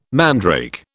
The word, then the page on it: mandrake: British English